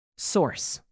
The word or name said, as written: source